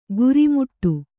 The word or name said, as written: ಗುರಿಮುಟ್ಟು